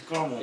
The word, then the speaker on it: caramel: It's caramel. – It says caramel is pronounced with 3 syllables. Caramel is a city pronounced with 2 syllables. – Caramel Mountain. – Yeah. – What am I going for? – That's spelled C-A-R-M-E-L.